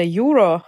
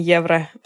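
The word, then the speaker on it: euro: Самое удивительное было, что, во-первых, именно там я узнала, что евро по-английски произносится «юро», а не евро, потому что я приехала и говорила евро.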